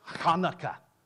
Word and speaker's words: Hanukkah: Hanukkah.